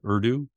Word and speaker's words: Urdu: Mr. Nadella asked it to translate a poem written in Persian by Rumi who died in 1273 into Urdu.